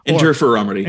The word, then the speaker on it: interferometry: And it gets into 3D non-contact optical profilers, which I like because they mentioned coherence scanning interferometry. I think I pronounced that correctly. – Interferometry.